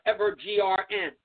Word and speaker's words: Evergreen: Last summer, we introduced you to a network available on smart TV sets and devices called Evergreen. That's how it's pronounced. It's done like Evergrn.